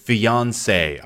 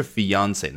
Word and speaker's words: fiancé: fiance 啊，一听多么洋气的发音，肯定不是英文这么土的语言具备的，对不对？是来自于法语 fiance 啊，指的是未婚夫。那如果是未婚妻呢？发音一模一样啊，至少在英文当中。然后呢，后面加上一个 e，多写一个 e 的字母，也是 fiance，那就是未婚妻。